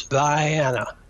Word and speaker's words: Vienna: I mean, it's just, and Vienna, Georgia is Vienna.